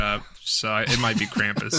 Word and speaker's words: Krampus: I'm pretty sure it's German. Jeremy, how would you say that? German. – Krampus. – Yeah, Hunter's right. Completely.